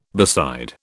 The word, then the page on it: beside: British English